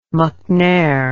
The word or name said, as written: mcnair